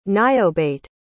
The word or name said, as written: niobate